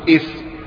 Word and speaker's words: اسم: نعم نعم فالاسم، لأن الألف هنا ألف وصل وليست ألف قطع، ونحن نعلم أن ألف الوصل تكتب وتسقط عند وصل الكلام وينطق بها في أول الكلام. عندما نقول اسم ظهرت الألف نطقا ولكنها تسقط في وصل الكلام، نقول باسم أو هذا اسمه.